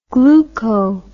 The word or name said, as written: gluco